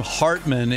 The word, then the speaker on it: Hartman: Well, the thing about my name, Hartman, is that heart rhymes with.